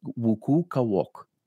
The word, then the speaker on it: Wuqu'Kawoq: Did I pronounce that anywhere near correctly? Wukukawok. – You did. Actually, it's perfect.